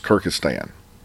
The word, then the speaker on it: Kyrgyzstan: If you want a real world example of the difference of the wussification of America today, look at, I think it's, to say it correctly, is Kyrgyzstan.